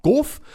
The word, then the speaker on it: Gauff: Ja, schreibt sich Gauff, G-A-U-F-F, spricht sich Gauff.